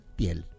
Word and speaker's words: Thiel: así que el apellido se escribe T-I-H-I-E-L, se pronuncia Thiel, Peter Thiel, que es alemán, nacido en Alemania, criado en Estados Unidos, tiene también la ciudadanía neozelandesa, tres ciudadanías tiene.